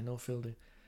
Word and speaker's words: Noel Fielding: Yeah, Noel Fielding.